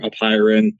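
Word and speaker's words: apeiron: There's a couple different ways of pronouncing it. There's Apuron, or if you want to be authentic to the Greeks, you try to roll the R's the best that you can. You could say it like Apuron or Apiron.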